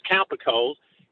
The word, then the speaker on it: Cowper-Coles: The other thing, important thing to note about this, a Bilderberger, Sherard Cowper-Coles, it's spelled Cooper-Coles, but it's pronounced Cowper-Coles, he was brought over to take over the MI6 Paris station, the France station, just a few weeks before the Diana assassination.